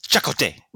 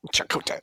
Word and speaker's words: Chakotay: Just based on what everybody said in this episode, I think you guys are pronouncing his name wrong. I think it's Chakotay. – Chakotay.